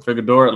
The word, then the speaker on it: Figueroa: Is it Figueroa? Is that Figueroa. – How you say it? – Yep. – Figueroa, like that.